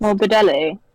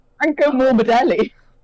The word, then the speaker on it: Franco Morbidelli: I said Frankie Morbido. – How is it pronounced? – Morbidelli. – Morbidelli. – Franco Morbidelli.